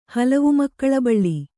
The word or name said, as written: ಹಲವುಮಕ್ಕಳಬಳ್ಳಿ